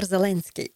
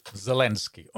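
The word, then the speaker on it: Zelensky (Зеленський): Volodymyr Zelensky. Zelensky, ja.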